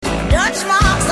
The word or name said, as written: deutschmarks